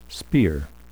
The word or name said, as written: spear